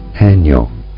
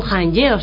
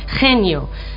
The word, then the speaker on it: Haenyeo: Las Haenyeo, o como se pronuncia en coreano... – Haenyeo. – Bueno, pues no son Haenyeo, son Haenyeo.